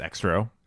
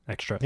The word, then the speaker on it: Xtro: X-T-R-O. – I think it's Extro. – Extro, yeah. – Maybe the movie will. – That's what I've been telling people. I hope it's not Extro.